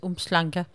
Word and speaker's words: Umhlanga: Altså, det staves Umhlanga, men det udtales Umhlanga.